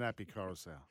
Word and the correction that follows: Koroisau: Api Koroisau.